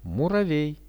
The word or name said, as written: muravej